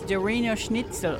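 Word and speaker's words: Der Wienerschnitzel: Did I say that right? – No, it's Der Wiener Schnitzel. – Thank you.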